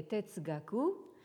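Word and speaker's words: tetsugaku (哲学): Alors, donc, vous avez ce composé qui se prononce en japonais tetsugaku et en chinois zheshue, et qui signifie littéralement donc xue, l'étude de zhe, c'est-à-dire donc de la sagesse.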